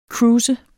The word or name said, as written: cruiser